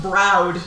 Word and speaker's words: Browed: Old Beetle Browed John. – Browed, not brown.